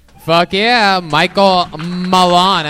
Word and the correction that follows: Malin: Am I saying that right, Mallon? – It's Malin. – Malin.